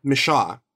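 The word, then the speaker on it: Micheaux: Michaud.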